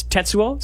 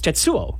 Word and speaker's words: Tetsuo: And in the first one, it's Tetsuo, so it's stress on the first syllable. In the second one, it's Tetsuo, which is not.